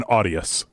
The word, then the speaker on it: Arias: It's John Arias. – I can't roll my R. That's, Arias. I can't do it.